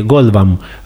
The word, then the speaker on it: Goldbaum: Goldbaum, así es, así se pronuncia bien,